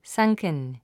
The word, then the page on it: sunken: sʌ́ŋkən; サンクン